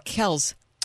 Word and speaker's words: Kelce: Because that's not how you pronounce it. It's Kells.